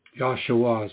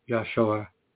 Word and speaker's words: Yeshua: Yeshua is a more correct pronunciation, by the way. It sounds like Joshua, Yeshua, the Savior.